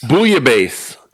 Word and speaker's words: Bouillabaisse: Sword Coast Seafood Bullabase? Bullabass? – Bouillabaisse. – Bouillabaisse.